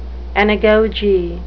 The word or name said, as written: anagoge